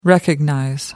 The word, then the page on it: recognize: American English